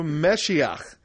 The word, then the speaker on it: Meshiach: Just thought you might like to know that Messiah comes from the Hebrew Meshiach. Rhymes with the side of lock. Oh, Messiach. There you go.